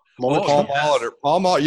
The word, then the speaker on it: Molitor: Molitor.